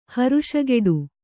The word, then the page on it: ಹರುಷಗೆಡು: kannada